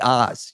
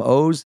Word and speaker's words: Oz: My name is super weird. It should be Oz. You know, blame my parents. I love them, but it's an Israeli name. So it's spelled O-Z the Mentalist. It's actually, yeah. So it's like Oz the Mentalist, but I'm O's.